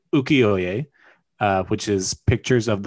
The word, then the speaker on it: Ukiyo-e: Ukiyo-e.